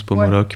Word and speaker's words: Pomoloc: Qui s'écrit comme ça se prononce, Pomoloc, ouais. P-O-M-O-L-O-C.